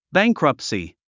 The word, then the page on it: bankruptcy: bǽŋkrʌptsi; バァンクラプトスィ